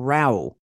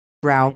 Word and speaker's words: Rau: Dr. Ray or Rau, I don't know how you say his name, R-A-U. Brow.